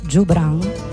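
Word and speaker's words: Joubran: Jobran, anzi, deve essere letto o studiato come la pronuncia di questo trio palestinese si debba fare con la G dolce come gelato, quindi Le Trio Jobran.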